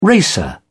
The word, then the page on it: racer: British English